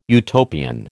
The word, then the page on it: Utopian: American English